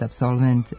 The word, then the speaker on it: absolvent: Ispravno je reći samo asistent, dirigent, absolvent, element. Ovako akcentovanje rezultat je pravila da posljednji slog u reči ne može biti naglašen.